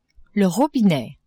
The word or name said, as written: robinet